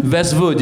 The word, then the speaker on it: Vestvood: Vestvood, you got it right.